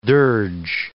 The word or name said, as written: dirge